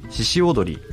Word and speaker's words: 鹿踊り: はい、ブランド名は漢字で鹿の角と書いて資格と読むのですが、これには 2 つ名前の由来がありまして、うん、1 つは私が 3 歳の頃からずっと続けている郷土芸能の獅子踊り、漢字では鹿踊りって書くんですけど、うん、読み方は獅子踊りって読みます、